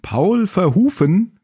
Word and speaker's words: Paul Verhoeven: Der Film ist von Paul Verhoeven, wie ich gelesen habe, wie er richtig ausgesprochen wird, den wir auch von anderen Filmen kennen.